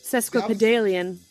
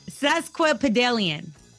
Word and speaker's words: sesquipedalian: Sesquipedalian. – There you go. Sesquipedalian.